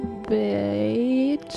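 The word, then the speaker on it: Bécs: Bex? With Fennesz? Bex. No, it's supposedly pronounced as similar to bitch. – Right. – Which is Hungarian, I believe, for...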